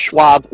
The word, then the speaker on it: Schwab: You did, just like Charles Schwab without the money.